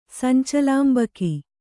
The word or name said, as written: ಸಂಚಲಾಂಬಕಿ